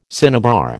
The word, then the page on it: cinnabar: American English